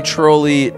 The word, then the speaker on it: Troli: T-U-R-L-D-J-C-H-R-O-L-L-Y. – So tweet Anthony Troli, T-R-O-L-I.